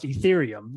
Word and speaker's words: Ethereum: It's pronounced Ethereum, which it's really challenging to pronounce for most people.